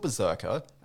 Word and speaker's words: BRZRKR: It's called Berserker, and that's spelled B-R-Z-R-K-R.